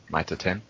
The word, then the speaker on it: Mitre 10: See, that's how you pronounce it, Adam. Mitre 10.